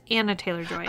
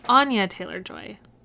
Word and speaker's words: Anya Taylor-Joy: I just want to see Anya Taylor-Joy, and I have been saying her name wrong the entire time. I thought it was Anna Taylor-Joy. – Oh, I make that mistake all the time, too. – It's Anya Taylor-Joy.